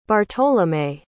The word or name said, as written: bartolome